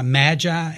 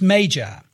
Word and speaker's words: Magi: And before we go any further, I've heard a lot of people pronounce Magi, Magi, and I did for most of my life, because I thought that that might be a secondary pronunciation of the noun, but it's not. The correct pronunciation, whether American English or British English, is Magi.